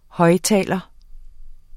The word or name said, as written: højttaler